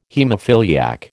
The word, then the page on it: haemophiliac: American English